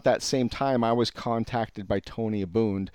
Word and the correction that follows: Abboud: uh, or uh, uh, Abode. Uh, how do you say his last name? Tony. Oh, uh, Abboud. Abboud.